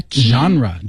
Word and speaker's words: genre: 这个单词大家一定要知道哈，怎么拼写来的 genre, G-E-N-R-E, genre.